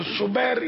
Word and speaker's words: شبیر: قول تو شبیر یعنی بر وزن حسین